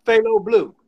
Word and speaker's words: Thaylobleu: Thaylobleu.